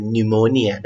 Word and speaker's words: pneumonia: แม้ว่าสะกดด้วยตัว P นำหน้าแต่จะไม่อ่านออกเสียง P นะครับผมอ่านเป็น pneumonia